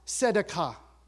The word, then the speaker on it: tzedakah: This particular one, in the Hebrew, it, it's pronounced tzedakah, right?